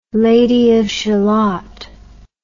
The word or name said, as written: Lady of Shalott